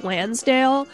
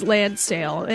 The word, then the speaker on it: Landsale: Right before that, from Japan, you heard P-Model with Missile from their album Landsale, which I keep pronouncing in my head as Lansdale, but it's not. It's Landsale, and it came out in 1980.